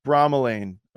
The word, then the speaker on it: bromelain: Q-U-E-R-C-E-T-I-N. And you want to get it with bromelain. Okay. That's B-R-O-M-E-L-A-I-N.